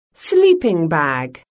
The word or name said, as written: sleeping bag